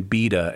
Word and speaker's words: Bede: So it would have been Beda at one time, but now it's just called Bede. Now he's just called Bede, and he's often called the Venerable Bede.